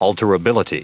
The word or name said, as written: alterability